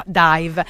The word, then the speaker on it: DIIV: si chiamano D-I-I-V, però appunto si pronuncia dive.